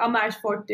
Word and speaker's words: Amersfoort: Amersfoort Jazz Festival de Hollanda'daydı. Oradaki festivale katılmıştık. Amersfoort diye okunuyor. – Hı ee, e, kolay okunabilir e, Dutch kelimelerden biri diyeyim.